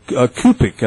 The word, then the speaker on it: Cupich: So there was an interview with a Cardinal Kupic, I believe is the way he pronounces his name, C-U-P-I-C-H.